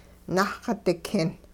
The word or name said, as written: nahx-dikin